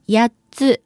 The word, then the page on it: 八つ: やっつ